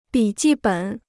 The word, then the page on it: 笔记本: bǐ jì běn